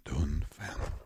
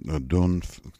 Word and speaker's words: Dongfeng: Если вам нужны китайские корни этого бренда, куда они уходят, то бренд называется Dongfeng. Дунфэн. Так он называется. Дунфэн.